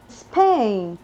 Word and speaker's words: Spain: Spain. – Spain, dice la S nada más.